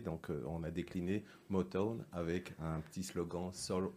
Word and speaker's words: Motown: Motown, prononce bien, s'il te plaît. Motown Oui, presque. Ah, vas-y, redis-nous. Motown. Motown. M-O-T-O-W-N, inspiré du fameux label euh, musical Motown.